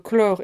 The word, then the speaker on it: clore: Le O de clore, comme vous l'aurez entendu, est ouvert et se prononce comme le O de robe ou de dehors. Deuxièmement, chlore, C-H-L-O-R-E, nom masculin.